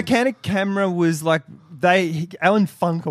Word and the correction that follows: Funt: It's pronounced Funt.